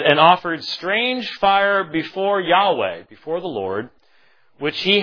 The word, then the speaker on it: Yahweh: And fire came out from the presence of Yahweh and consumed them, and they died before Yahweh. I'm reading the word the Lord in its Hebrew pronunciation.